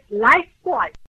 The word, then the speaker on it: Lifebuoy: O sabonete estimulante, agradável, refrescante, que se escreve Lifebuoy, tem pronúncia bem correta, é bem simples e discreta. A pronúncia é Lifebuoy.